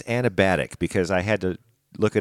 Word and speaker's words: anabatic: A-N-A-B-A-T-I-C. – So you're trusting Google? – Yeah. – Maybe it's like acrobatic. Anabatic. – Anabatic. Yeah, okay.